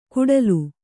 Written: ಕುಡಲು

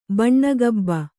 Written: ಬಣ್ಣಗಬ್ಬ

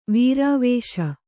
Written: ವೀರಾವೇಶ

